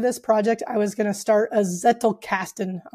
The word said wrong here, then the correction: Zettelkasten: I'm saying that wrong. It's a German word. – Yeah, Zettelkasten.